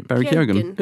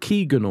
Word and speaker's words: Keoghan: Is that how you pronounce Barry's name? – Barry Keoghan. – Yeah. I always thought it was something like Keegan or Keegan.